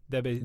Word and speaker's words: Debussy: Debussy.